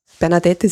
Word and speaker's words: Bernadette: Bernadette ist ein französischer Name. – Ja. – Und daher wird der letzte Buchstabe des E nicht ausgesprochen.